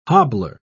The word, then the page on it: hobbler: British English